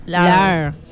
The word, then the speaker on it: lore: ¿Cómo se pronuncia del inglés? – Lar.